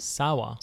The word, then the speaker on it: Sawa: Is it Devin Sawyer? Is that how you pronounce it? – Sawa. – Sawa, okay. Yeah. I wasn't sure. – Did you see a Y in his name? – No.